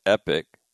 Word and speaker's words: Epictetus: And it's spelled E-P-I-C-T-E-T-U-S. And you hear it pronounced a lot of different ways, right? So it's Epictetus. You hear it pronounced Epictetus. Or more emphasis on the E.